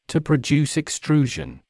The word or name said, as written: produce extrusion